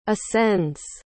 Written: ascends

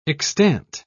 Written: extant

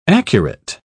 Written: accurate

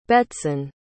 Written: Betsan

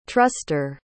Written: truster

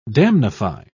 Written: damnify

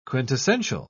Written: quintessential

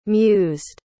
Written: mused

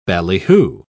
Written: ballyhoo

